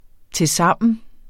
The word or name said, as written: tilsammen